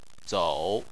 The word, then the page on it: zou: 3